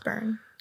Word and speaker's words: Byrne: Anyway, it is that David Byrne, and David Byrne is pronounced Byrne. Because he's like English or whatever.